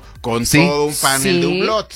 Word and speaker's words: Hublot: Hublot.